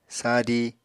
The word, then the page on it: sadi: Apatani